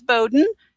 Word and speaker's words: Bowden: So Z-A-N. And then my last name is Bowden. It's two syllables. It's bow, like what little girls wear in their hair, and den, like where a bear lives. So it's B-O-W-D-E-N.